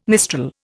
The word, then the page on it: mistral: Indian